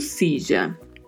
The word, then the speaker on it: silla: E, mais uma vez, a pronúncia do duplo L, né? Cidia, Cidia, Cicha, Cilha, dependendo da região. Então, o nosso dicho popular de hoje é: Quem se foi a Sevilla perdeu sua silla.